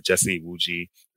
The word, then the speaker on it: Jesse Iwuji: So just look up my name, Jesse Iwuji. That's J-E-S-S-E. Last name is I-W-U-J-I.